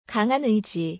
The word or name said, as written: 강한 의지